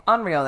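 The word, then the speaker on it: Onryō: A-O-N-R-Y-O. – Onryo. – We'll say Onryo. – So I had a football player that I coached called Rio. And his name... That's how you pronounce it. And his name is spelled R-Y-O. – Okay. Onryo then.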